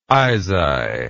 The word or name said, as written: isai